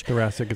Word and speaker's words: thoracic: He, of course, after the Tommy John surgery, had thoracic outlet syndrome. Correct me if I'm pronouncing that wrong. Which thoracic is right.